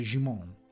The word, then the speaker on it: Djimon: Eh, l'ho pronunciato alla francese perché ho capito che dovrebbe essere Jimon la pronuncia del suo nome, da quello che ho visto anche da un'intervista.